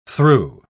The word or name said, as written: threw